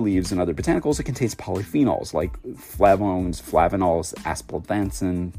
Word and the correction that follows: aspalathin: But boy, did I butcher that name. Aspal, aspalathin is what it's called. I call it aspalanthin.